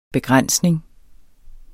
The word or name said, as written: begrænsning